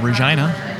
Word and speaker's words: Regina: Landed in Regina. That's how it's pronounced.